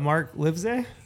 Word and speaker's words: Livesay: It's L-I-V-E. – Okay. – Like live, S-A-Y.